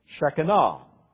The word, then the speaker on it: Shekinah: Probably it'd be more correctly pronounced Shekinah.